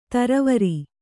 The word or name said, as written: ಥರಾವರಿ